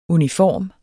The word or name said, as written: UNIFORME